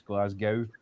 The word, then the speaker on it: Glasgow: Glasgow is like, but the last three letters of Glasgow are GOW, so it gets mispronounced Glasgow quite a lot, but it's just Glasgow.